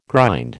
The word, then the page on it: grind: American English